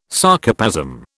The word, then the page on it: Sarcoplasm: British English